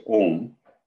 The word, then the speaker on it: Om: The mystic symbol, pronounced Om, at the center is Atman.